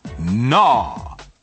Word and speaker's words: gnaw: Gnaw. – Step 1. Listen carefully. 仔细听。 – Gnaw. – Gnaw. – Gnaw. The G is silent.